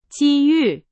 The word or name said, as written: 机遇